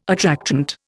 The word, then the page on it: attractant: Indian